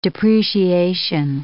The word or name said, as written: depreciation